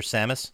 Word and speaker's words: Samus: Has it always been a thing in Metroid where like Seamus? Is it Seamus or Samus? – Samus. – Samus? – Seamus. – Well, that's how it's spelled. – Samus. No, it's not. Seamus has an E.